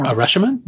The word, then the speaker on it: Rashomon: No, am I saying it right? No, Rashomon.